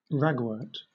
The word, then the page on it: ragwort: British English